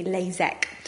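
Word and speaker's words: LASEK: First of all is the LASIK, which is spelt with an IK, and the other one sounds exactly the same, but it's spelt with an EK, LASIK.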